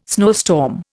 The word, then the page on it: snowstorm: Indian